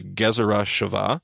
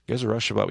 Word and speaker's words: Gezerah Shavah: We talked about Jesus doing this, called a Gezerah Shavah.